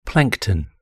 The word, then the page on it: plankton: British English